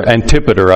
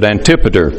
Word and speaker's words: Antipater: Antipater, I think, is the way most people pronounce it, but Antipater.